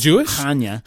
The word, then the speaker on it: Chania: It is pronounced Chania. It's Chania.